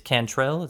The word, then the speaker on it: Cantrell: It's, it's Cantrell. Is that how you say your last name? Yes, sir. Yep.